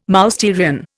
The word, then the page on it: Mousterian: Indian